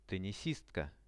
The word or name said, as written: tennisistka